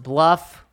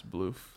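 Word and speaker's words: BLUF: I always get confused because I think it's supposed to be pronounced bluff. Ryan says bloof. – It's bloof.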